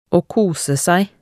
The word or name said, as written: å kose seg